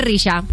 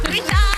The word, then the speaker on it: Rizha: Y es imposible pronunciar así de mal el nombre de nuestra siguiente colaboradora. La hemos llamado de todo menos su nombre. Es Risha. ¡Risha!